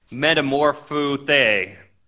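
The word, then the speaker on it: metamorphothe (μετεμορφώθη): And he was metamorphothe, is how you say it in Greek.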